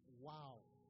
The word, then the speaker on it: Vav: That is represented by the Hebrew letter called Vav, originally pronounced Wow.